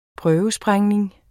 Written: prøvesprængning

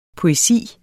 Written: poesi

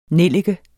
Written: nellike

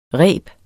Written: rebets

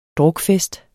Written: drukfest

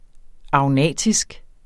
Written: agnatisk

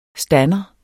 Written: stander

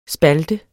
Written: spalt

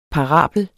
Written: parabel

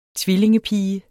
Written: tvillingepige

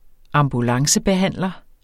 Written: ambulancebehandler

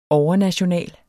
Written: overnational

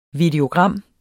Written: videogram

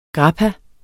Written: grappa

